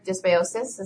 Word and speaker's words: dysbiosis: One was that I had severe dysbiosis.